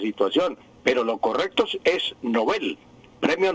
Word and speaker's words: Nobel: Decíamos Nobel, y resulta que no es Nobel, sino Nobel. Así se pronuncia el sueco y así pasa al español, porque repito, los nombres extranjeros pasan al español con la misma pronunciación que tienen en su idioma original.